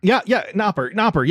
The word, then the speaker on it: Knopper: Knopper, yeah, that's his name.